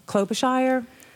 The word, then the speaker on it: Klobuchar: I think I heard an interview with Klobuchar.